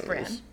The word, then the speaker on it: Bran: See, I knew it was Brandon, but I was like, well, maybe they just pronounce it Braun for some reason. But no, it's Bran.